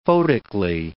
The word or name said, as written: photically